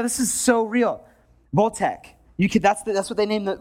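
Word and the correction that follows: Wojtek: Sorry. Voitec. Voltec sounds like a... Voitec is the bear's name. It's spelled with the W.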